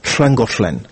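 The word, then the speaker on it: Llangollen: Типа 2L произносится «шл». Это очень часто употребляется в названиях валлийских городов, типа «шлангошлен» или «шланаманах».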